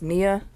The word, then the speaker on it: Nia: Her name is the same as my sister's, and it's pronounced Nia, so she's going to just take that.